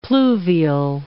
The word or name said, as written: pluvial